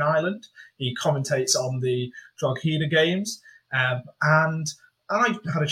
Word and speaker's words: Drogheda: And as we've mentioned, I'm now joined by David Sheehan from LMFM Radio, the regular commentator for Drogheda, as it is pronounced, as I have double-checked, because, let's be honest, nobody knew.